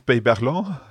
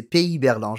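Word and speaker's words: Pey-Berland: Okay, toi tu as déjà fait la tour Pays Berland avec les 282 marches. D'ailleurs, je l'ai très mal prononcé. Je pensais que c'était Pays Berland. Je n'ai jamais visité la tour, donc c'est Pays Berland.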